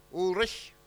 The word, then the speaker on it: Ulrich: Ulrich.